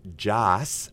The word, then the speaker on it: Joss: Joss Wheaton. I always think I say Josh.